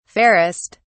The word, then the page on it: fairest: British English